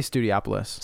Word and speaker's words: Studiopolis: I say Studiopolis. – Studiopolis. – You know what, guys? I just say lights, camera, action. – Because it's like Metropolis. It's you say Metropolis.